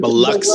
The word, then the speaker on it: Biloxi: And we need to teach your students the correct way to say the coastal city Biloxi. We have heard people mispronounce it Biloxi because it's spelled that way. And it's like, no. It's Biloxi. – Biloxi. Yes, yeah. Biloxi. – Biloxi. Got it.